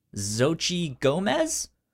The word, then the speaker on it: Xochitl Gomez: And then there's Xochitl Gomez, I believe is how you pronounce her name.